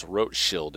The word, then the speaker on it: Rothschild: Rot is the German for red, R-O-T, the Rote Sterne, like the Red Star books. Rothschild, red sign, red shield. Commonly, the last syllable is pronounced as if it were child, that is, if we anglicize it to the Rothschild, it sounds more English, but it's Rothschild in German.